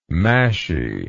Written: mashy

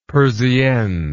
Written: persiennes

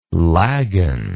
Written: lagen

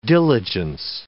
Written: diligence

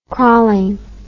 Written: crawling

